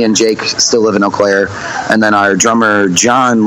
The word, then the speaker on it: Eau Claire: It's Eau Claire.